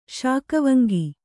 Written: ಶಾಕವಂಗಿ

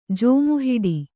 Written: ಜೋಮುಹಿಡಿ

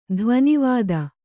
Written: ಧ್ವನಿವಾದ

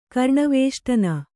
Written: ಕರ್ಣವೇಷ್ಟನ

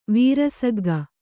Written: ವೀರಸಗ್ಗ

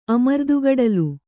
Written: ಅಮರ್ದುಗಡಲು

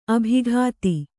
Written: ಅಭಿಗೀತ

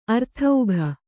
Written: ಆರ್ಥಿಕ

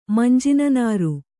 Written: ಮಂಜಿನನಾರು

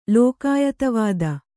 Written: ಲೋಕಾಯತವಾದ

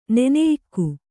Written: ನೆನೆಯಕ್ಕಿ

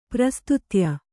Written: ಪ್ರಸ್ತುತ್ಯ

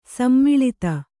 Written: ಸೋಮಲತೆ

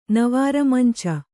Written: ನವಾರಮಂಚ

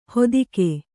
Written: ಹೊದಿಕೆ

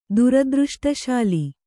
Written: ದುರದೃಷ್ಟಶಾಲಿ

